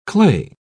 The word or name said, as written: clay